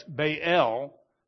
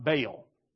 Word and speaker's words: Baal: In Hebrew, when you get two vowels, it's usually two sounds. You got to say them side by side. That's why it's Baal, not Baal, like most of us say. You got to pronounce both of them.